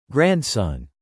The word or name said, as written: grandson